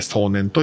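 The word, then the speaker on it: 送年: の、裏音で、裏音ラジオでやってるこの壮年のコーナーっていうのがあるんですが、これはあの、送る年と書いて壮年と読みます。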